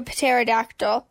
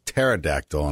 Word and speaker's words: pterodactyl: And then there's pterodactyl. And I think. – No, but because the P is silent.